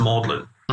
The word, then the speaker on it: Magdalene: It's like, it's like Magdalene when it's pronounced Maudlin.